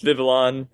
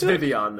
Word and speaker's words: Vivillon: It's Vivian, though. Vivian. Yeah. It is a French pronunciation.